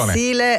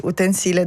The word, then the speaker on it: utensile: Utensile è quando è un sostantivo, quindi utensile da cucina. – Okay. – Invece utensile, quindi l'accento sulla E, quando è un aggettivo.